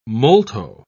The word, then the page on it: molto: British English